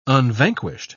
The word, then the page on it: unvanquished: British English